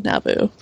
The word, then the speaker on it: Nauvoo: Nauvoo, yeah. I always pronounce that wrong, Nauvoo.